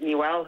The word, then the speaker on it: Niwel: Do you pronounce it Newell? N-I-W-E-L. Newell Goes to Town. – Yeah, Newell, yeah.